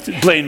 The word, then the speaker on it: Blaine: Plain Blaine, Minnesota. Blaine, Minnesota, I should say it right.